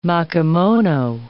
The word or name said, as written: makimono